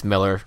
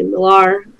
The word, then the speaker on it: Millar: in that he says it's Miller. – That's one of those things that I know intellectually, and I think even when I said his name just minutes ago, I stopped myself like mid syllable, like, I know I should say Miller, but I just want to say Millar.